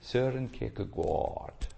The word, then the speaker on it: Søren Kierkegaard: You say it, Søren Kierkegaard. To be pronounced here. It's a Danish name, and he says, God work, he speaks about how we are shaped or put into shape by Krishna.